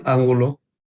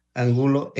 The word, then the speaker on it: Angulo: Manuel Angulo. – Can you please spell it? – M-A-N-U-E-L, Angulo, A-N-G-U-L-O.